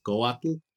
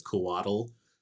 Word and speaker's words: Couatl: It's pronounced Coatl in Nahuatl. Um, and that and that's my like Hispanicized Nahuatl. Like if I got if we actually got an indigenous Nahuatl speaker, they probably say it differently too. That TL sound tends to throw people off, but it, it's okay if you pronounce it as Kuatl, um, with like almost like you're saying waddle. That's fine.